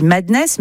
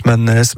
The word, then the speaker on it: MDNS: Ultra-violent, avec, je crois, on dit Madness, mais ça s'écrit MDNS. – Ouais, MDNS, Madness, Mad.